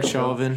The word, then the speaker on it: Chauvin: Do you guys think it was interesting, back on, like, the Derek, I don't know, how do you say his name? – Derek Chauvin. You can say Chauvin.